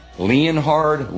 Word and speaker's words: Leonhard: H-A-R-D, Leonhard. – Everything is not what it appears. There are a lot of names that are not pronounced the way they are spelled, including Jim Leonhard.